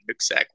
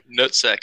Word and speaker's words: Nooksack: Me, I'm up in Nooksack, Washington, kind of a little hometown where I grew up.